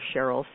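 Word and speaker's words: Sherrill: And if you are, please go over to my website, Dr. Cheryl Selman, and I'll just spell that because it's not your normal Cheryl. It's S-H-E-R-R-I-L-L, Selman, S-E-L-L-M-A-N.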